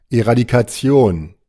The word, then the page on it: Eradikation: German